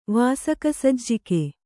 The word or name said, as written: ವಾಸಕಸಜ್ಜಿಕೆ